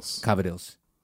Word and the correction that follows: Cavatelli: Called Cavatelli. The way it's spelled.